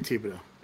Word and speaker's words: Thibodeau: I always say Thibodeau. – There's no H, so Thibodeau, right? – Right.